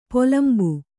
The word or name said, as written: ಪಲುಂಬು